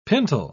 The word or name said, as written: pintle